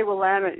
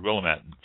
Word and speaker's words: Willamette: But it just struck me as strange because I've always heard Willamette, so the accent on the end.